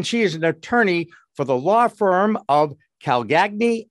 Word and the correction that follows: Calcagni: Lauren, please tell me I pronounced those names correctly. it was very close. Great to be with you. It's Calcagni and Kanefsky.